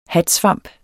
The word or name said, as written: hatsvamp